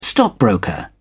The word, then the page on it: stockbroker: British English